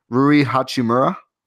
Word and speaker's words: Rui Hachimura: Rui Hachimura.